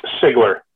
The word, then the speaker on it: Siegler: Pronounce it as if the first E is not in there. – Right, because I heard the Deep Purple podcast guys saying Sigler, but I just wanted to confirm for definite that it is. All right, that's great. – Basically ignore the first E, and then it'll probably come out right.